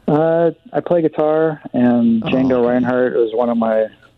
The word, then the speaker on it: Django: Close enough. Django, the D is silent.